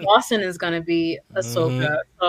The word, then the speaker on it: Ahsoka: Yeah, but I did watch the animation one where uh, Ahsoka, Ahsoka, that's how you say it.